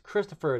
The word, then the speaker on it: Christopher: And like, how do you pronounce this? Christoph? Christoph Fee? I'm like, it's Christopher.